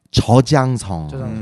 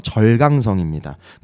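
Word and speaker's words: 저장성 (浙江): 그런 다음에 저장성. 한국어 발음 절강성입니다.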